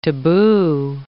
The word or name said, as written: taboo